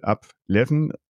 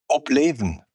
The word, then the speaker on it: Obleven: Und du hast ja Obstalsboom Hotel Deichgraf, hast du ja zum Ort der Stille, der absoluten Stille, Ableven. Ist das richtig ausgesprochen? Ableven? – Obleven. – Obleven. – Das ist Plattdeutsch und heißt Aufleben.